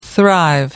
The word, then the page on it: thrive: American English